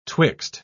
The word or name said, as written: twixt